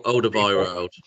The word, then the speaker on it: Alderweireld: How to pronounce it? – O- Older Byrod. Older Byrod, that's it.